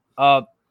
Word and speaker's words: Chalamet: Timothy Chalamet. Is that how you pronounce his name? Chalamet? – Yeah, Chalamet.